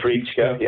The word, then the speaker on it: Treitschke: Heinrich von Treitschke. – Treitschke, yeah.